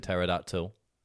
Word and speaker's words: pterodactyl: Like T for pterodactyl. It begins with a P.